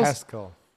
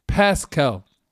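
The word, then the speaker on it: Paschal: Dann Josh Pass, Pass, spricht man den Pascal von Kentucky aus? – Pascal. – Pascal.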